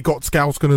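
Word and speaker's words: Gottskálksson: Ollie Gottskalsken as well. Is that how you pronounce it? – Yeah, Ollie, the goalkeeper.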